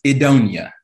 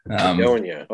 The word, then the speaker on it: Edonia: It is Edonia. – Edonia. Okay. – Edonia, which is originally comes from, it's a play on Eden, was where it originally got its name from, the Garden of Eden that humanity was kicked out of according to the Christian faith.